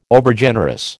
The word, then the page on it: Overgenerous: American English